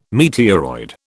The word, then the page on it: meteoroid: British English